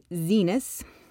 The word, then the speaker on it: Zenas: Or when they investigated this patent examiner, who was a man named Zenas. If you watch the drunk history on this, you can remember it because it rhymes with penis.